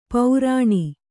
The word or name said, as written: ಪೌರಾಣಿ